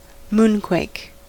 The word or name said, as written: moonquake